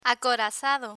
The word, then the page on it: acorazado: Spanish